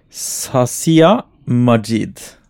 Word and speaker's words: Sasia Majid: Eh, skrevet av Sasia Majid. Som er en Korrekt, eh, korrekt uttale.